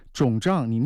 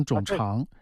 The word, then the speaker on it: 肿胀: 你有些字你都全都念错了，有些字你都不会念，肿胀你念成肿肠，哎呦我的天老爷，我都听半天我都听不懂，反正就是整个肩周啊，你就是一个肩周炎颈肩综合症啊，肩周炎加上一个颈肩综合症，应该来说在疼痛科的医生指导之下做一些局部治疗效果是非常好的。